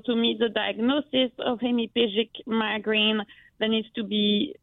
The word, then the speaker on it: hemiplegic: What is a hemiplegic migraine? I don't even know if I'm saying that correctly, but what is that? – Yeah, you're saying it perfectly.